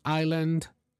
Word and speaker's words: island: In the case of the letter S, the silent S appears in several words, including island, isle.